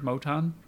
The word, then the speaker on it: Moton: How do you say his name? Is it Moton or Moton? – I heard Moton. – Okay, well, Moton sounds good.